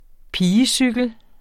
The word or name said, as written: pigecykel